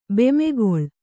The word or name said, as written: ಬೆಮೆಗೊಳು